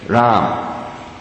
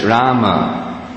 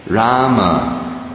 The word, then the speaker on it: Rama: But what I've understood is, in Hindi, it's preferred, Ram. But in Sanskrit, it's Rama. And the number, you count the number of syllables, the math is real quick, it's Rama. To get 16, and then the other 16 gets 32, so it's Rama. It's not Krish and Ram. It's Krishna and Rama.